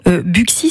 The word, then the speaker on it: Buxy: Buxy serait le seul nom de commune situé dans l'ancienne province bourguignonne, comportant un X au milieu, hein, où ce X se prononce X.